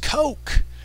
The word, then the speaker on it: Koch: It's pronounced Coke, as in Coke whore.